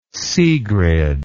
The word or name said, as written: sigrid